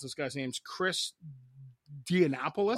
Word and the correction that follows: Diamantopoulos: Oh, Diamantopoulos or Diamant? Yeah.